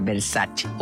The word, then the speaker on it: Versace: Por ejemplo, Versace, cuando la marca Versace es italiana. o sea, tendría que pronunciar Versace.